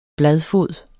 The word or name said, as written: bladfod